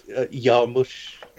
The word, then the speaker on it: Jarmusch: And then when choosing a film this time, I was looking at however you want to pronounce his name, Jim Jarmusch. – Jarmusch, Jarmusch.